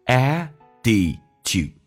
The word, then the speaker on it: attitude: Have it, T 去掉略音， with your 重度卷舌，整个嘴巴凸出来，来体验一下 your， one more time， your 最后一个单词叫 attitude， attitude， T 发 D 这个发音，很简单，两个元音中间所加的辅音要被浊化，来体验一下 attitude，我们重新来朗读一遍， colors may fade from bright.